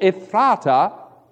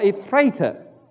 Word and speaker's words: Ephrathah: For instance, Bethlehem of Judea, also called Ephratah or Ephrathah, depending on how you want to pronounce it, that little city is a terribly important one.